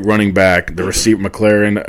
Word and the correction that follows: McLaurin: or McCullen. What I can't. I always, McLaurin. McLaurin. I always pronounce his name wrong.